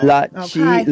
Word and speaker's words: Lachi: Lachi like Versace, girl. – Lachi like Versace.